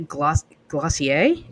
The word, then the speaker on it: Glossier: Glossier? Is that it? – It's supposed to rhyme with dossier.